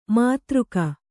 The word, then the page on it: ಮಧುರಿಕೆ: madhurike